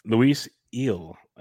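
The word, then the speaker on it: Luis Gil: Luis Eel, as the way I heard them pronounce it on the Yankees broadcast. – Good. I'm glad you said it first. – Yeah, I would have gone Gill. I had friends last name Gill my whole life. But the G is silent, it sounds like.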